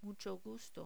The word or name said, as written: mucho gusto